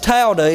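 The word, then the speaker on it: towdah: There is a word for, there's a Hebrew word for pray, and it's pronounced Tawdah. It's T-O-W-D-A-H.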